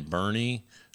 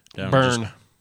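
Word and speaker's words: Boerne: So in 2019, they gave away a scholarship to a young man in Bernie. – Burn. – It's not Burn. – It's pronounced Burn.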